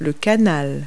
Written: canal

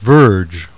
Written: VERGE

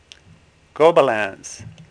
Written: Gobelins

